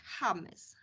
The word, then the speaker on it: James: I jeżeli znamy kilku piłkarzy, takich, takie, takich piłkarzy legendy, czyli James Rodriguez, James, który pisze się James, ale to jest James, James Rodriguez, Falcao, Y, to, to jest tak, taki drugi piłkarz.